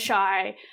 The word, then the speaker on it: Chey: And it took me 3 months to tell them that my name was Shy. – For the listener's perspective, their name is spelled C-H-E-Y.